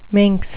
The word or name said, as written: minx